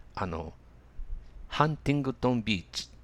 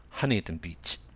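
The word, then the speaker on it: Huntington Beach: そのちょっと上がですね、ハンティングトンビーチっていう市があるんですよ。で、そのハンティングトンビーチという発音をテレビニュースで、日本のテレビニュースで聞いたときに、日本語ではこういうのかと思ったんですね。では、英語の発音ではどういうかというと、ハニントンビーチ、ハニントンビーチと言います。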